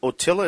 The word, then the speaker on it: Otilla: Otilla, is that how you pronounce her name? – I think so. That's how I've been pronouncing it, yeah.